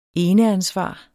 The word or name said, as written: eneansvar